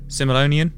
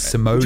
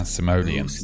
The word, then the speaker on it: Simoleon: That's not the right pronunciation of it, though. – Rico, find the Rico. – Yeah, that's not. It's simoleon. – Would you like to give us the correct? – Yeah, simoleon.